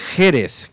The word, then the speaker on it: Jerez: Ah, no, es Jerez, con acento en la E primera.